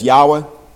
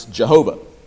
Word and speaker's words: Yahweh: It's four Hebrew characters that the scholars like to call the Tetragrammaton, what we would spell out in English as YHWH, and that the scholars prefer to pronounce in the modern day as Yahweh, but traditionally in the church, and as you've noticed this morning in our hymns, we pronounce Jehovah, and there's reasons for why the name has been turned in that direction that we don't care to get into here today.